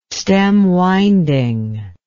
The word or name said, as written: stemwinding